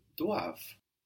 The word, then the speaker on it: doivent: They must is il or elle doivent. DOIVENT. So also spelt with that silent ENT on the end.